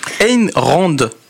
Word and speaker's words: Ayn Rand: s'est inspiré d'un travail d'une philosophe américano-russe qui s'appelle, c'est compliqué, hein, Ayn Rand.